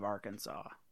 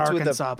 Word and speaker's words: Arkansas: No, 30% of Arkansas. – 30, just that silent S.